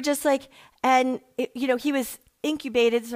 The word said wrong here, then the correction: intubated: or whatever you call that. I never can say it right, but intubated. Well, how do you say it? Intubated, thank you.